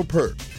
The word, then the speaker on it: Peart: Nigel Peart. – Peart.